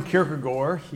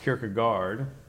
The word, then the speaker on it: Kierkegaard: Soren Kierkegaard, maybe you've heard it pronounced Kierkegaard.